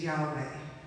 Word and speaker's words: Yahweh: That name is Yahweh. The commentary I read suggested that inhaling was the first syllable of Yah, and the second syllable, Wei, is the exhale.